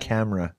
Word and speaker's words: Chimera: Chimera, right? Chimera? – What's that? – Chimera is like an amalgam of two. – It's pronounced camera. It's camera.